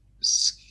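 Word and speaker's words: skyr: that like skew yogurt, or whatever, I don't know how to say it. The Icelandic one. – Isn't it? – Skew. Yes, that's how you say it.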